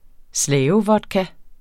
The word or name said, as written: slavevodka